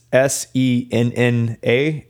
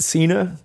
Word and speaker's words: Senna: It's S E N N A, and I think they want that all in caps. I'm not sure, but not going to pronounce it Cena, but Senna. It's not John Cena.